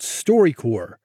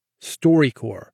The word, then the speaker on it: StoryCorps: It's called StoryCorps. And you spell it C-O-R-P-S. StoryCorps.